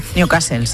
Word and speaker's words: Newcastle: Newcastle, sí, sí.